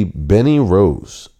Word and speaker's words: Bennie Rose: Now, the person who I am choosing for my pick of the week is going to be Benny Rose. Now, this is an artist called Beanie Rose, and it's spelled B-E-N-N-I-E-R-O-S-E.